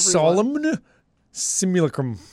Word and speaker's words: Solemn Simulacrum: Solemn Simulacrum. Just pronounce every letter very flatly like that.